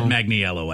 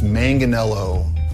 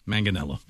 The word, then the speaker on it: Manganiello: So how do you say it? – It's Manganello. – Hi, I'm Joe Manganello. – Manganello.